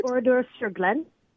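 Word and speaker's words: Oradour-sur-Glane: Oradour sur Glen.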